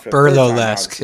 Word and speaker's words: burlesque: I think I said that wrong. – Burlesque is the correct pronunciation.